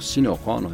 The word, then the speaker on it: Sinéad O'Connor: Os problemas de Sinéad O'Connor com, ou Sinéad O'Connor, de acordo com a pronúncia, com a depressão, já haviam sido revelados, né, e destacados pela imprensa na época.